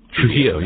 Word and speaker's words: Trujillo: Pardon me for the mispronunciation. Adrion Trujillo. – Trujillo, yeah.